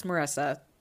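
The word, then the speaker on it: Marisa: You were right with your first guess, Caleb. It's Marissa with two S's, but just spelled with one.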